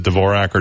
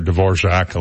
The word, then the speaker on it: Dvorak: So this guy had, 10 years ago, had gone with a you can pronounce it Dvorak or Dvorak, like the composer if you want.